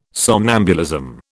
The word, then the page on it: somnambulism: British English